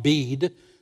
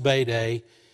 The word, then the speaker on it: Bede: It looks like Bede. It's pronounced Bede.